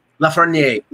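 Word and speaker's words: Lafrenière: But what we're talking about is that was Lafreniere's first, and I'm butchering his name. I'm sorry. I'm just going Lafreniere.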